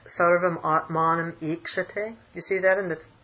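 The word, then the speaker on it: Ikshate (ईक्षते): There's a short E there, Ikshate, on your... – Mm-hmm. – It should be long. It should be Sarvam Ikshate.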